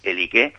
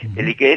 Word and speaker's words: Elique: Bueno, pues Iliqui, a la que los iberos llamaron Elique, y ojo, Elique, terminado en eta, es decir, en e larga, en castellano tendríamos que poner el acento en la e final, Elique. Elique es el nombre que le dan los griegos.